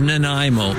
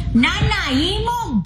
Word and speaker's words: Nanaimo: But it is Nanaimo, a wonderful historic indigenous name. – Nanaimo.